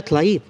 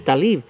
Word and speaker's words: Tlaib: yo oigo que le dicen Talib. Rashida Talib. Pero, pero eh, la L está antes de la A. Es T-L-A-I-B. Mm. Yo lo pronuncio Tlaib. Pero oigo que los noticieros dicen Talib.